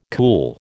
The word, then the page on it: cool: American English